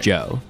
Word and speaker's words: Djo: Joe. Spelled D-J-O. The D is silent. – The D is silent. And not like D-J-O.